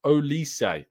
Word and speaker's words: Olise: It's pronounced Olise. Oh, like Michael Olise.